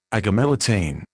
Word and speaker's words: agomelatine: Active ingredient, agomelatine, pronounced agomelatin.